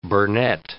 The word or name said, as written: burnet